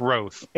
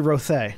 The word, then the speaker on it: Rothe: I believe the correct pronunciation is Roth. – It's actually Rothe. There's an accent over the E.